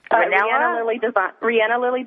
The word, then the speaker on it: Rihanna Lily: Rihanna Lily Designs, like Rihanna.